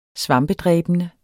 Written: svampedræbende

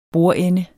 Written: bordende

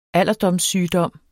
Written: alderdomssygdom

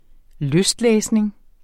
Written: lystlæsning

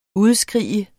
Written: udskrige

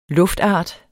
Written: luftart

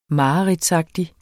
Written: mareridtsagtig

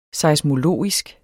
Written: seismologisk

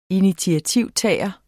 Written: initiativtager

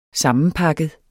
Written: sammenpakket

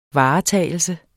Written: varetagelse

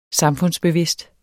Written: samfundsbevidst

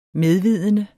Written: medvidende

